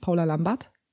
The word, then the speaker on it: Paula Lambert: Ja, und dann haben wir eben Paula kennengelernt, Paula Lambert.